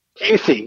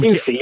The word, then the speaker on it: Huessy: You know, so I'm, Huessy, yeah. – You know, I should type it out phonetically because I'm a douche. So there you go. – Hue like the color, C like the letter.